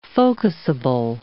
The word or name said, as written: focusable